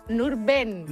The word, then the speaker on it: Nurben: Yo creo que te falló una vocal. A ver, repítelo. – ¿Nurdin? – Nurben. – Nurben, como suena. – Y además es muy curioso porque... No, tal como se escribe. Uh-huh. Porque no hay ninguna I. Es no, Nurben no, pero Yesef.